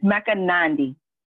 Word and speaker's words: Mecca Nandi: And it's Mecca Nandi.